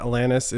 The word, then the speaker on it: Alanis: The fact of the matter is that Alanis is how you say her name.